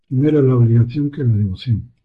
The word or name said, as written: devoción